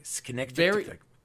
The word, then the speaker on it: Schnicktick: Yeah, no, like, if you don't use the SCH as a sh sound, it's more of a hard C, like a K sound, like that could be Schnick-tick.